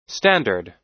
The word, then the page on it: standard: American English